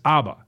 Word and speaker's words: ABBA: See, that's how Now, I got a text from the 414 saying I was not pronouncing the group ABBA correctly, I may have said ABBA. So I checked it, and I went to one of those things where a soft-spoken British man pronounces words for me, and he said it's ABBA. So ABBA is the correct pronunciation.